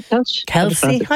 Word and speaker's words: Kelce: And she's getting ready to marry the three-time Super Bowl champion, Travis Kelce. Is that pronounced it correctly? – Kelce? I would have said, yeah, go on. Yeah, great. Yeah.